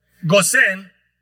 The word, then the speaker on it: Gosén: Cuando salieron de Goshen, ¿cómo se dice Goshen en español? Gosén, un lugar de muchos recursos, tierra verde, mucha agua.